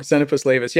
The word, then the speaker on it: Xenopus laevis: Why Xenopus laevis? How do you pronounce it? Frog. – Xenopus laevis, yeah.